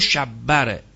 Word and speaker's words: شبر: این شبیه رو شبر رو قبلا گفتیم که اسامی فرزندان هارونه که به عربی برگرداندن میشه حسین و حسن، که بعضی میگن شبیر و شبر، اشتباه تلفظ شبیر و شبره، شبیر و شبر تلفظش هم جناب نراقی پدر، نراقی پدر در کتاب مشکلات العلوم تلفظش صحیحه، شبیر و شبر رو آورده.